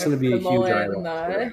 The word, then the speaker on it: ayahuasca: Does a lot of ayahuasca tea drinking. – Is that how you say that? I had never seen that word before, so I had no idea how it's pronounced. – Yeah, okay. Ayahuasca. But yeah, like if you're like a stoner hippie, like you drink ayahuasca in the forest and like run around the leaves.